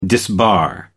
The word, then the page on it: disbar: American English